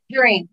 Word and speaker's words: Jereen: Jereen, Leah, like a tangerine.